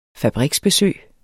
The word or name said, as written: fabriksbesøg